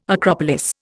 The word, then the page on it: acropolis: Indian